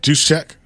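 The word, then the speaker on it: Juszczyk: How do you say it? – Juice check. – Is that right, or are you just saying that? – I think that's right.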